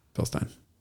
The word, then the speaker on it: Bilstein: And so how are you supposed to pronounce Bilstein? – Bilstein. Bilstein. Bilstein in German.